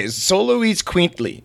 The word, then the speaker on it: Xoloitzcuintles: Okay, Xoloitzcuintles is how it's pronounced.